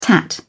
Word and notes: tat: British English

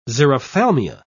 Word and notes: xerophthalmia: British English

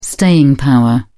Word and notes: staying power: British English

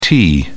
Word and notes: te: British English

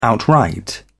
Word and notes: outright: British English

